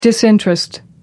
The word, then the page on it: disinterest: American English